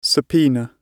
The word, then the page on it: subpoena: British English